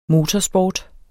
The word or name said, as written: MOTORSPORT